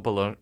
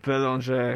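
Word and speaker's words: Bélanger: I feel like you probably butchered Anna's name there. I assume it's Dumont Belanger. – Belanger. – It's a French name.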